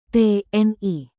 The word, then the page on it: tni: abbreviation